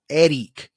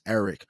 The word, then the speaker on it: Erik: You know, or, you know, Eric would be the way in most Latin people would pronounce Eric.